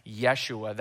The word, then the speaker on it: Yeshua: Now, when they would have said Jesus' name, they would not have said Jesus. They would have said something that sounded like Yeshua. That's how they would have pronounced it.